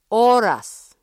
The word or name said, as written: oras